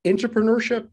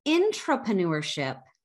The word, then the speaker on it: intrapreneurship: Before we get into that, you've coined a word, entrepreneurship. Did I pronounce that correctly? – Yes, you did. – And what is that? – Intrapreneurship.